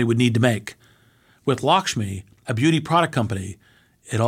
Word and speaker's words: LXMI: She's the founder and CEO of two companies, Samasource and Lakshmi. Lakshmi is spelled LXMI, by the way.